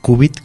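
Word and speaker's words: cúbit: Entonces, perfecto, es un qubit, se prefiere esa escritura, qubit con acento en la U y con C, uh-huh, y no las formas en inglés que tienen que ver con la letra Q.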